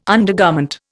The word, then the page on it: undergarment: Indian